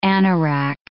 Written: anorak

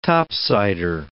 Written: Top-Sider

